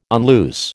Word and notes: unloose: American English

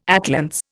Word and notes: atlantes: Indian

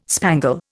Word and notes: spangle: Indian

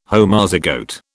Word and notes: Homozygote: British English

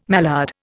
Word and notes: mallard: Indian

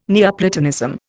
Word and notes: Neoplatonism: Indian

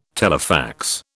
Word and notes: telefax: British English

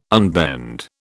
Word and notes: unbend: British English

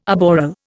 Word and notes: aboral: Indian

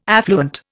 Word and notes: affluent: Indian

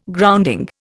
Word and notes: grounding: Indian

